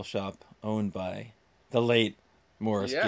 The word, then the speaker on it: Maurice: because as Americans we pronounce it Maurice. – Yes, it's Morris. It's Morris. That's the British spelling. It's Morris Gibb. – Yes, yes. Even though it looks like Maurice.